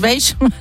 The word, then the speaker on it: beige: No, eso no es marrón, eso es beige. – Claro. – Eso es más clarito, eso es un blanco roto. – ¡Ay, qué bien pronunciado! Beige.